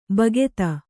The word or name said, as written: ಭಕ್ತ